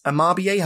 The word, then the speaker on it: Amabie: You were saying that, like, this Amabie, have I said it right? – That's right, yeah.